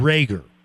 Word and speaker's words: Reagor: Reagor. Am I pronouncing his last name correctly? – Yes.